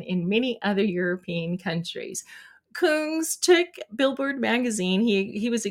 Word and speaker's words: Kungs: The song is a collaboration between French house artist King. Oh, it's pronounced Kungs of Melbourne, Australia funk trio Cooking in Three Burners.